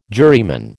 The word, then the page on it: juryman: American English